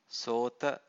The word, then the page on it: sota: Pali